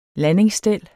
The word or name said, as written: landingsstel